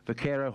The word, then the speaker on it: Vaquero: Vaquero horsemanship.